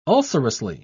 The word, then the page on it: ulcerously: British English